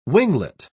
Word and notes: winglet: British English

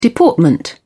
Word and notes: deportment: British English

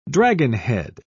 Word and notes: dragonhead: British English

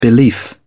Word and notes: belief: British English

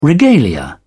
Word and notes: regalia: British English

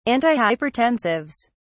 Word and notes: antihypertensives: British English

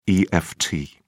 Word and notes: eft: British English

